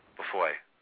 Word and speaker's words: Beaufoy: I'm butchering his name, but it's Simon Beaufoy. It's B-E-A-U-F-O-W. How would you? – Beaufoy.